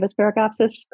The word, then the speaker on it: Asparagopsis: Is it Asparagopsis? Am I saying that right? Asparagopsis? – You are. And we have actually invented our own variety of Asparagopsis called Brominata.